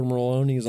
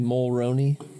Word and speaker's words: Maloney: How do you say his last name? Mulroney? – Wow. Maloney, right? – It's got an R in it, doesn't it? – I don't think so. – It's Maloney.